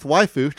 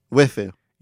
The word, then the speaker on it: waifu: You are the big titty goth waifu trapped in a dating sim. – No, you got to say it right. – Waifu. – Yeah, there you go.